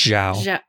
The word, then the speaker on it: Zhao: I think it's Zhao.